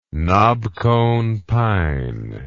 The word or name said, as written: knobcone pine